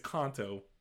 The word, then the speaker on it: Kanto: I mean, Kanto, or sorry, he actually corrected. It's Kanto. I was like, oh, that's how it's pronounced.